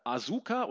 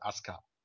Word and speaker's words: Asuka: Äh, Asuka oder, oder Asuka, wie sie, wie sie ausgesprochen wurde von, von den Amis. Ähm, nee, nicht nur von den Amis, das U ist still.